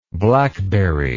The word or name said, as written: blackberrylike